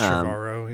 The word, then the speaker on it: Trevorrow: I mean, you really could see why Spielberg chose this kid, Colin Trevorrow, or whatever you say his name, Trevorrow. It's like Trevor Tomorrow combined. – It's Trevorrow, yeah.